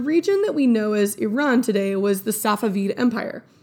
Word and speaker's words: Iran: Quick note before we get started, it's pronounced Iran. It's not a Flock of Seagulls song, y'all. It's not Iran.